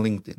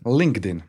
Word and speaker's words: LinkedIn: A, u jednom razgovoru si mi spomenuo i da LinkedIn, ne znam kako se to uopće izgovara. – Ja to zovem LinkedIn. – LinkedIn, može.